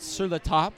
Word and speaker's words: Sur La Table: Rachel Frederick, VP and GM of e-commerce at Sur La Table. Yes. – Did I say that right, Anne? Sur La Table? – You did.